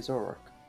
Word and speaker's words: Zoroark: It's like Zoroark or something like that. Weird. – Zoroark.